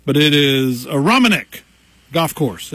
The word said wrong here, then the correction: Aronimink: Did I get that right? A Romanek? Is that how you say that? – Aronimink. – Aronimink. See, I'm from McDowell County, Pete.